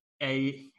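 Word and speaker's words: Aiyuk: You've got Ayuk, is that how we say his name? – Yeah.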